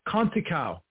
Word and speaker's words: Conceição: Is it Conceição? – He's Conticao. – Conticao, okay.